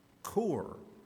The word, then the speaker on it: cour: And they took this three-letter word, C-O-R, that means core, heart, and they changed the spelling to C-O-U-R, which I think is pronounced core.